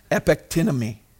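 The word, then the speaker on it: epectynemi (ἐπεκτείνομαι): I like saying these because it's a challenge to pronounce them. It's epectynemi. Epectynemi.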